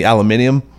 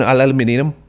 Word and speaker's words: aluminium: That is the one word, however, in our language that I wish we did say like the Brits. I believe I do side on the aluminium.